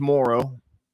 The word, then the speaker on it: Morrow: So, like, my last name's Morrow.